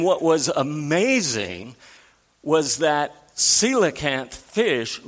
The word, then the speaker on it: coelacanth: I know the word looks like coelacanth, but it's pronounced coelacanth.